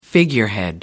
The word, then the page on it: figurehead: American English